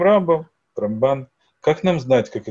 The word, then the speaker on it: Рамбам: Рамбам всегда говорят с ударением на первый слог. Рамбам.